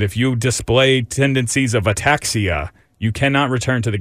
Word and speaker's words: ataxia: Tagovailoa rules, he was not allowed to return because of the, I don't know how to say this word, Dave. Can you help me? – Ataxia? – Oh, thank you. Is that it? Ataxia clause.